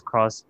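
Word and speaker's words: Cross-Z: Although although this name the spelling of the name is cross dash z, but it's pronounced as cross, which is play on word of claws.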